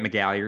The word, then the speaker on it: McGallier: My name is Grant McGallier, and you can find me on Twitter at Grant McGallier. It's spelled like it sounds with all those vowels.